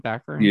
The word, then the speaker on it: Baccarin: Baccarin? – Yeah.